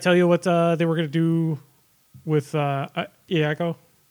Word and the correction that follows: Iago: Oh, Iago. – So, yeah, Iago. Sorry, I pronounced it wrong.